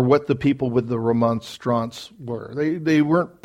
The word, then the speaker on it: Remonstrance: which leads us to the Remonstrance.